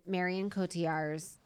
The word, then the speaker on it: Marion Cotillard: And that is in the scene in Marion Cotillard's.